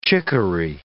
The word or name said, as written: chickory